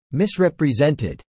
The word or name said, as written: misrepresented